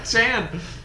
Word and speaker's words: Tan: So we can start first with is it pronounced Tan? It is pronounced Tan. Yeah, Tan. That's how it's called.